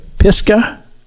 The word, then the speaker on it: Pisgah: Pisgah? – Mm-hmm.